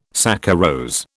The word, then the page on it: saccharose: British English